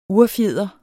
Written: urfjeder

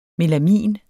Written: melamin

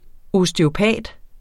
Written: osteopat

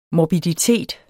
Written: morbiditet

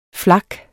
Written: flak

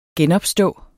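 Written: genopstå

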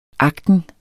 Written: AGTEN

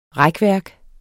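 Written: RÆKVÆRKERNES

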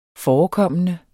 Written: forekommende

